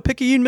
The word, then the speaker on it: Picayune: You know, Picayune, Mississippi.